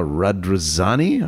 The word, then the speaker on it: Radrizzani: And they are now owned by a new owner, an Italian gentleman, Andrea Radrizzani, I believe. Is that how you pronounce it? – Beautifully pronounced, yes.